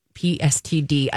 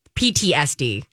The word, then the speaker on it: PTSD: PSTD attached to that. PTSD.